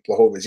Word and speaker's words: Błachowicz: Błachowicz. He's a title contender. I got to pronounce it properly.